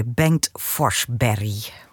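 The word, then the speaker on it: Bengt Forsberg: En op orgel hoorde je Bengt Forsberry. Zeg ik het goed zo, Jacobus? – Mooi.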